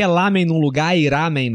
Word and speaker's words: ramen: E eles falam ramen. Essa é a pronúncia certa. Que não é nem L nem R. É ramen. – Ramen.